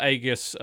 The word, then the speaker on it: Aegis: So I can actually give you information on this because I randomly picked up Aegis of Earth, or Aegis of Earth is the correct pronunciation, on PS4.